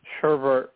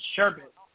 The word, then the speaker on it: sherbet: Sherbert with an R, definitely. – yeah, well, I mean, there's no R. There's there's only one R. It's sherbet.